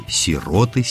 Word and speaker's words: сироты: Есть только одно правильное ударение – «сироты», «сироте», «сиротам» и так далее.